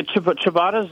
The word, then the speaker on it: ciabatta: Yeah, ciabatta is just a rustic Italian bread, and you can use any crouton you like. We went to our local bakery. We use a lot of focaccia and ciabatta at our club.